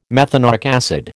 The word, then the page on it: methanoic acid: American English